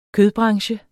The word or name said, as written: kødbranche